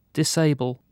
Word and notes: disable: British English

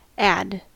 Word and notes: ad: American English

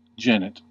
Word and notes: genet: American English

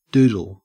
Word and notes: doodle: Australian English

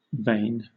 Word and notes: vane: British English

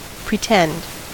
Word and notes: pretend: American English

